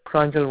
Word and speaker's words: Pranjal: This is Pranjal Varma. – Okay, sir. Can I have your name, please? – Yeah, Pranjal Varma.